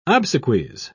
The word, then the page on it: obsequies: British English